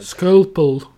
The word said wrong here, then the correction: scalpel: What is a skulpel? You mean, you mean scalpel?